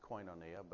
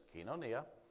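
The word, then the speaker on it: kinonia (κοινωνία): Most people pronounce it koinonia, but the modern Greek pronunciation is kinonia, as an association.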